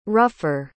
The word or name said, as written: ruffer